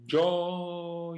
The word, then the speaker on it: gio: So the letter I here serves only to soften the G to the phonetic G, and then there is no I pronounced at all. – Quindi gio, ya.